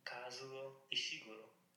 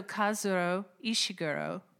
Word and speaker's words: Kazuo Ishiguro: Kazuo Ishiguro. So Kazuo Ishiguro, um, Never Let Me Go is the book I'm going to talk about.